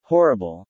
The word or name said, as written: Horrible